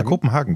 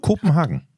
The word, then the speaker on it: Kopenhagen: Also das ist eine Frage, sagt man wirklich Kopenhagen? Mhm. Und ich habe es immer falsch ausgesprochen, weil ich sage immer Kopenhagen. – Mhm. Tatsächlich, ja, Kopenhagen wird das ausgesprochen. – Kopen oder Koppen? – Mhm.